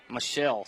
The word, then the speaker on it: Michael: And Orangefield faithful, if you're listening, we did get a pregame warning that it is pronounced Michelle. Looks like Michael, but pronounced Michelle.